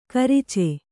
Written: ಗೋರೋಚ

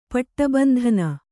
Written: ಪಟ್ಟಬಂಧನ

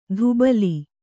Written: ಧುಬಲಿ

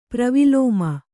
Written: ಪ್ರವಿಲೋಮ

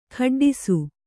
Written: ಖಡ್ಡಿಸು

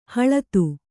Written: ಹಳತು

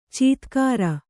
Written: ಚೀತ್ಕಾರ

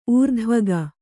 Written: ಊರ್ಧ್ವಗ

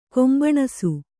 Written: ಕುಂಭಾನಸ

